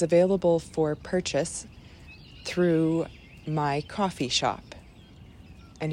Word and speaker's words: Ko-fi: Well, it's K-O-F-I. And I'm pretty sure it's pronounced coffee because their whole shtick is that you give folks a cup of coffee.